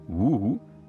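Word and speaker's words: wu (五): Each dancing figure has the Chinese number 5 carved into its shoulder, probably because the word for 5, wu, is phonetically the same as the word for dance, which has a far more complex character.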